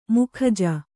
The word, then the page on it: ಮುಖಜ: mukhaja